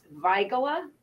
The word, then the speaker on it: Weigela: Oh, and I should tell you, one of my favorite gardening books here, the word Weigela is actually pronounced Weigela after Christian Ehrenfried, and I'm massacring this because I don't know German, von Weigel, who was a German botanist, 1748 to 1831.